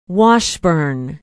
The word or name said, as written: washburn